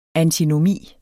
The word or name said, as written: antinomi